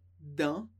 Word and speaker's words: dans: Primeiro, dans. Dans. Sem pronunciar o S, que significa em ou dentro de.